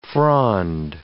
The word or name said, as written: frond